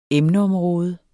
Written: emneområde

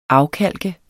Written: afkalke

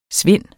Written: svind

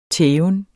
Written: tæven

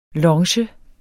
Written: longe

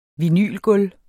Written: vinylgulv

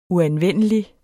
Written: uanvendelig

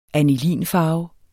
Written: anilinfarve

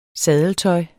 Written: saddeltøj

